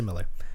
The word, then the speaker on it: Millar: It's written by Mark Millar or Mark Miller, depending on how you want to pronounce it. I go with Millar. A lot of people say Miller.